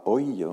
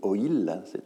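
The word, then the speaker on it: oïl: Je rappelle qu'on ne dit pas oil, on dit oil.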